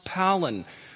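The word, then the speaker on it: palin (πάλιν): It happens to be a word pronounced palin, which is translated again, it's a, it's a change of subject.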